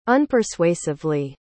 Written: unpersuasively

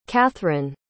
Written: cathrine

